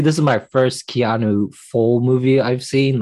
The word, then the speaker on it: Keanu: So the choice for this movie with the superstar Keanu, is that how you say it? – Keanu Reeves. – How do you say it again? – Keanu.